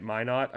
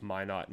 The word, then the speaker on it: Minot: I think I'm saying that right. Minot. I believe it's Minot, not Minot. Minot.